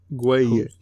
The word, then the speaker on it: Gueye: How do you say his last name? Gueye. That's what I'm saying.